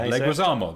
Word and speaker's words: Leguizamo: Isn't Leguizamo.